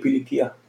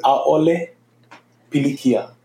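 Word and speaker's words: A'ole pilikia (ʻAʻole pilikia): Ha'ole pili kia.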